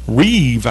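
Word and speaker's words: Rêve: And it is R-E-V-E is the name of this coffee blend, if you will. And it's Reeve, I guess is how it would be pronounced. It's R with the accent over the E-V-E. Reeve.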